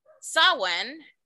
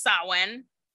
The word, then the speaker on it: Samhain: It's Samhain, but it's pronounced Samhain. It's